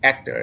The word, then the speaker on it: Hector: Yeah, but you just don't pronounce the H. The proper way to do it is Hector is his name.